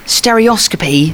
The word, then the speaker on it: stereoscopy: I am with Brian May at Proud Gallery, Central London, um, for the stereoscopy 3D photography exhibition.